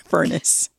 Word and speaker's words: Furness: I mean, it's spelled differently. It's spelled F-U-R-N-E-S-S, but yes, Furnace, Lady Furnace.